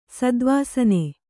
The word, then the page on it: ಸದ್ವಾಸನೆ: Kannada